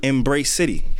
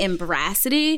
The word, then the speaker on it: Embracity: I just want to compliment you for saying the name right, because a lot of people say Embracity, and I'm come on. – How is it spelled? – E-M-B-R-A-C-I-T-Y. So it's embracing yourself within the city.